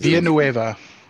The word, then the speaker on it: Villeneuve: Villeneuve. – Villeneuve.